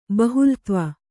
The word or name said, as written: ಬಹುಲತ್ವ